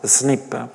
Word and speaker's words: SNP: Mais très important, tout au long du jour aujourd'hui, du cours aujourd'hui et de, la reste des cours, vous allez m'entendre parler de SNP, prononcé comme ça, SNP.